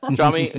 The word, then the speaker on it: Jiaming: Oh, this is Jiaming. I can say, I can say my name first. Jiaming, you can find me on Twitter, J I A M I N G L O U.